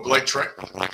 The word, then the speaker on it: Blake Treinen: Oh, Blake Treinen.